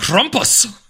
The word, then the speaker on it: Krampus: I'm pretty sure it's German. How would you say that? German. – Krampus. – Yeah, right. Completely.